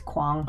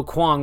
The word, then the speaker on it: Quang: Also, pronunciation check on that one for me, if you can jump in. – I would say is Quang. – Oh, Quang with the O sound.